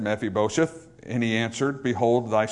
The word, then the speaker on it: Mephibosheth: Now when, we oftentimes pronounce this name Mephibosheth. I do that a lot of times as well, just because it's easier to do so. But the correct pronunciation is Mephibosheth, which is a little bit different than Mephibosheth. It'll mess up a couple songs, but it's actually pronounced Mephibosheth.